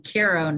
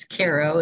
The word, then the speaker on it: Cairo: I have a tree behind me now, and uh, within a mile of the hospital I was born in, in Cairo, New York they pronounce it Cairo when it's in Egypt, but it's Cairo in the Hudson Valley.